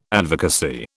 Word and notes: advocacy: British English

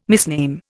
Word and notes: misname: Indian

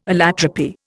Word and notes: allotropy: Indian

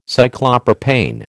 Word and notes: Cyclopropane: American English

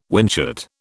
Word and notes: whinchat: British English

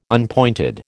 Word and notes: unpointed: American English

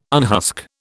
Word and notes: unhusk: British English

